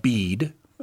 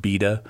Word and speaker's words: Bede: Well, okay, so first of all, the name is pronounced Bede. In the olden days, it would have been pronounced Beda because all those silent E's we have in English— They didn't used to be silent.